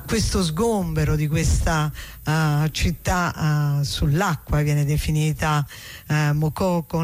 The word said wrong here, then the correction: Makoko: Mokoko con l'accento? – Makoko, sì, non so se è con l'accento finale alla francese, Makoko, però probabilmente siccome quello è un paese anglofono sarà in maniera diversa.